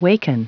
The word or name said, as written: waken